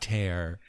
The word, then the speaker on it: tear: See what I mean? It's pronounced tear.